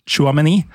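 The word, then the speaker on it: Tchouaméni: Aurelia Chouameni.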